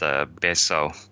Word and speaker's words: Betio: The capital area of Tarawa is spelled B-E-T-I-O, but it's pronounced Beso.